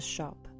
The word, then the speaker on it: scop: I act as a shop, an old English word spelled S-C-O-P, but pronounced like a retail shop.